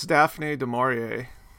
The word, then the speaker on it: Daphne du Maurier: Well, I think it's Daphne du Maurier, and she's a pretty prolific and famous and successful author.